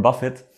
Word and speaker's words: Buffett: sieht wahrscheinlich keinen Namen, der häufiger falsch geschrieben wird als Warren Buffett mit einem T am Ende und das wird ja dann so ausgesprochen wie Buffet.